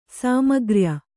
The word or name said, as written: ಸಾಮಗ್ರ್ಯ